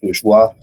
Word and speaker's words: Bourgeois: My LinkedIn's Mike Bourgeois.